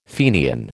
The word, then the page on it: Fenian: American English